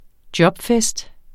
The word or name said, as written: jobfest